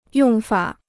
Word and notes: 用法: yòng fǎ

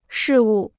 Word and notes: 事物: shì wù

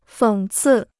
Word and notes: 讽刺: fěng cì

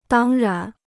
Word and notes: 当然: dāng rán

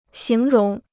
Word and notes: 形容: xíng róng

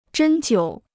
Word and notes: 针灸: zhēn jiǔ

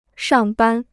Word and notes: 上班: shàng bān

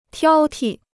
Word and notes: 挑剔: tiāo ti

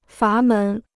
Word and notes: 阀门: fá mén

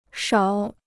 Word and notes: 少: shào

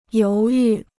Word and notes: 犹豫: yóu yù

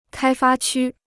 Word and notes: 开发区: kāi fā qū

